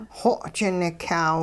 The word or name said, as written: xoji-nikyaaw